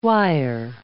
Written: wire

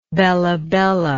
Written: bella bella

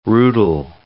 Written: rootle

